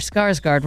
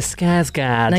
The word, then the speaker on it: Skarsgård: Though I think I pronounced Alexander Skarsgård wrong. – Yeah. – How do you say it? – Alexander Skarsgård. – Now, yes, thank you.